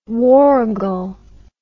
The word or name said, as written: warragal